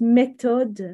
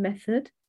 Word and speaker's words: méthode: English words that are pronounced in a fresh way, a French way, exactly the same word, pretty much, but it's pronounced méthode rather than method.